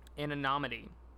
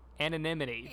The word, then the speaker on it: anonymity: It's because as an actor, there's a level of anonymity. I said that wrong. Anonymity.